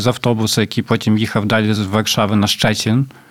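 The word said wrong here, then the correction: Szczecin: Боже, я досі не можу вимовити Щечин нормально. – Щечин.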